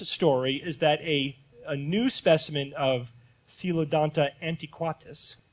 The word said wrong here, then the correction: antiquitatis: I missed a syllable, antiquitatis, was found.